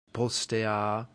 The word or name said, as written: postea